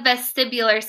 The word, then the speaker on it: vestibular: So what about how do you say that? The vestibular? – It's the vestibular system. So basically it's, yeah. – Oh, okay. Mm-hmm. I got it. – You did get it right.